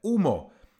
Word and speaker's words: humo: E, dřív se psalo jako facer, s f na začátku, nebo humo, psáno tedy z h, vyslovujeme umo, se dřív psalo jako fumo, tedy sv, ano.